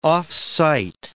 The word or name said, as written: off-site